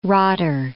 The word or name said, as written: rotter